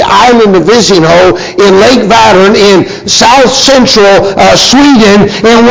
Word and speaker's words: Vättern: This lake is called Lake Vättern, V-A-T-T-E-R-N, is how we pronounce it in English.